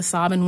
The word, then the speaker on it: Sabine: So they have this old book up in the mountain on the farm talking about the Sabin women where, and that's how they pronounce it, by the way.